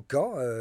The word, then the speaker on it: Khan: Khan, Kubilai, comme nous disons en français. Comme vous le savez, on écrit K-H et on prononce R avec une laryngale en Mongolie et en Chine.